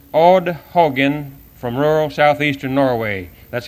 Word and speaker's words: Haugen: And we pronounce his name, H-A-U-G-E-N, Hogen, in English.